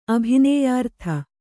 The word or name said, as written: ಅಭಿನೇಯಾರ್ಥ